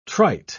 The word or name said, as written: trite